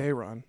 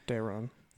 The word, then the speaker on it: Daeron: I think it's Daeron. That's how I say it. Yeah, Daeron.